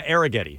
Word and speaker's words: Arrighetti: Spencer Arrighetti? – Yeah, Arrighetti. – I believe is how you say that, yeah.